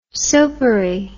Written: soapery

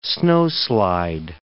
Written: snowslide

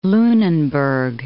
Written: Lunenburg

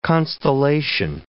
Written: constellation

